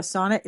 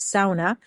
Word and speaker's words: sauna: Okay, so I went to Finland last year and I learned that a sauna is not a sauna. It's pronounced sauna. The Finnish people are very specific. They said, we have one word that you have to pronounce correctly in English and you can't get it right. It's not sauna, it's sauna.